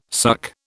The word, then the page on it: suck: British English